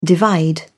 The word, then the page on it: divide: British English